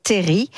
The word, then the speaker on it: terril: Et il faut savoir qu'à l'origine, on écrivait terri sans le L, comme on le retrouve dans les premières versions, d'ailleurs, de Germinal, d'Émile Zola. Et donc, la bonne prononciation, c'est terri, et on dit vivant,